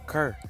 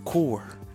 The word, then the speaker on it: Kur: And I was saying his name wrong back in the day. I called him Kerr. I'm saying? Gang told me it's core. Like Tupac Shakur, but just the core.